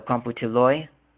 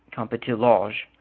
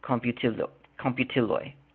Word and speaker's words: komputiloj: Plural nouns end in an OJ, and the J is pronounced as a Y. So computiloi, it's actually spelled K-O-M-P-U-T-I-L-O-J, computiloi. Ekranoi, that's a J at the end. So it looks like computiloge, but it's computiloi. J is pronounced as a Y in Esperanto.